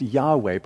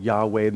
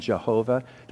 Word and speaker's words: Yahweh: And it's pronounced Yahweh, probably the most accurate, Yahweh. The most popular has been Jehovah.